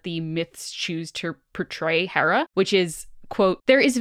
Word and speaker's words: Hera: So yeah, Hera, or it could also be pronounced Hera, both are correct.